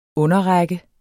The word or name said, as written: underrække